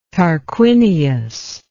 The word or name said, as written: tarquinius